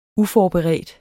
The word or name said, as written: uforberedt